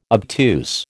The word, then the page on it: obtuse: American English